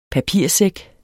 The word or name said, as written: papirsæk